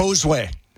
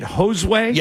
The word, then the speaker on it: Josue: Okay, pronounce that guy's first name again. – Josue. – That's how you say it. Josue.